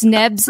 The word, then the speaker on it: Nebs: So you can still pronounce Nebs. It's Nebs instead of Nabs, but like it's still.